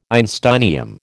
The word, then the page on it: einsteinium: American English